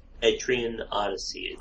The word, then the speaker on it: Etrian Odyssey: Etrian Odyssey.